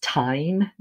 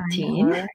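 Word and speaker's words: tine: Okay, I don't know how to pronounce the sail one, but the fire word, it's pronounced tyne, not teen.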